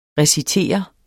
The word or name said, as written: recitere